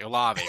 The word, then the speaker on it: Olave: How did you pronounce it? You said Olive Garden? – I meant, sorry, Olave Garden.